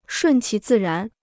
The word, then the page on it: 顺其自然: shùn qí zì rán